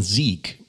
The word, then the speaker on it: Zeke: Also Z-E-K-E geschrieben, ausgesprochen Sieg.